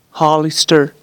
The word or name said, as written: Hollister